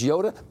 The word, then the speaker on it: Iota: It could be named Tropical Storm Iota if that happens. I understand the Greek pronunciation is Yoda, but that's a possibility looming out there in this record-setting hurricane season.